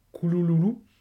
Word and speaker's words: Cthulhu: Là aussi, vous pourrez voir euh, sur le net, il y a des vidéos où il indique que ça pouvait se prononcer quelque chose d'assez étrange, genre Koulouloulou,